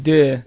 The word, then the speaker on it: deux: It's pronounced deux, is it asshole.